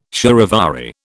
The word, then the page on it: charivari: British English